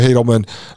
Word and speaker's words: Haldeman: It says, on October 13th, 1940, a Regina chiropractor named Joshua Haldeman, and that's, I've heard people pronounce it different ways. It's H-A-L-D-E-M-A-N. So I'll say Joshua Haldeman, appeared in city court to face two charges under the Defense of Canada Act.